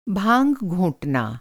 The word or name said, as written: घोंटना